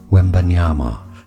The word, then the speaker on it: Wembanyama: It is, say it with me, three syllables. Wembanyama.